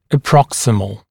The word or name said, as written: approximal